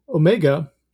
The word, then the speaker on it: Ohmega: So Omega, which for listeners, if they don't know Omega, it's spelled O-H-M-E-G-A, which is a play on ohm.